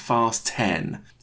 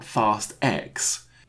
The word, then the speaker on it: Fast X: They said, this film's title is pronounced Fast 10. It is not Fast X.